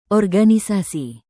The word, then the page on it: organisasi: Indonesian